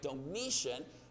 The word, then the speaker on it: Domitian: We know that there is a Roman emperor, and I want to say his name right, Domitian, who has exiled John to this island.